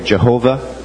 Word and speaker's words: Jehovah: Which would be pronounced Yahweh or Jehovah.